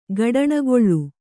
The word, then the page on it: ಗಡಣಗೊಳ್ಳು: gaḍaṇagoḷḷu